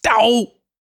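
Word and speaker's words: DoH: First, we saw Firefox start rolling out support, and well, now we've seen some trouble and headlines about DOE support in Chrome. – Killing me, Wes. You got to stop mispronouncing that. It's Dow. Yeah, okay, you're right. That's way better.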